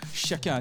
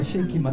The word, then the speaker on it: Shake It: 네, 발음을 주의하셔야 됩니다. 쉐키 아닙니다. 쉐이킷입니다. 네, 그 썩는다 그 쉐이킷 맞죠? – 네.